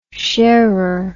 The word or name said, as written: sharer